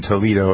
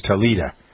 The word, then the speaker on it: Toledo: And one of the things I learned in becoming an Ohioan, a Buckeye, was how to pronounce Toledo. It wasn't Toledo. It was Toledo.